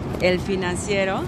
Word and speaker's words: El Financiero: El Financiero.